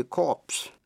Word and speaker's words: corpse: Corps, C-O-R-P-S, corps là một sư đoàn. The medical corps là sư đoàn quân y. Số nhiều, đọc là corps. The Marine Corps, sư đoàn thủy quân lục chiến. Phân biệt với chữ corpse, C-O-R-P-S-E là xác chết. Vậy corps và corpse. Psychology, tâm lý. Psalm là thánh ca, P-S-A-L-M. Receipt, giấy biên lai, R-E-C-E-I-P-T. Coup, C-O-U-P, là một cuộc đảo chính.